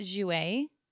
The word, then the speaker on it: Jouer: I know you like, is it Jouer or Jouer? – So I pronounce it Jouer.